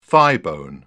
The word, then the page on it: thighbone: British English